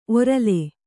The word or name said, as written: ಒರಲೆ